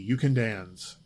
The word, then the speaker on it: Ukandanz: It's spelled U-K-A-N-D-A-N-Z, You Can Dance.